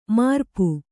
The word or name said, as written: ಮಾರಾಪು